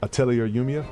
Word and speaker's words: Atelier Yumiya: And as such, I can't figure out how to pronounce the name of it. – Mm-mm. – Atelier Yumiya? – Atelier Yumiya? – Yeah. Yeah. Yeah. Okay. Atelier, sure. Yes.